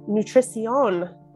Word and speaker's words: nutrición: We are set to talk about some nutrición. Is that how you say it in Spanish? – Nutrición. – Thank you. You do it better.